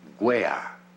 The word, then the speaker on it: wear: Entonces, ponemos la W de wash y también ponemos la W de wey. – Oye, espera un momento. No se dice wey. Se dice wear.